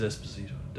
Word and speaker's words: Esposito: Esposito. – Oh, he is, yeah. It is Esposito.